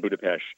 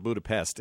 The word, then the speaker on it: Budapest: It has that Central European pre-World War II feeling, and that's still there in places like Vienna and Budapest and Prague. – And you got to learn how to say it properly. It's not Budapest, it's Budapest.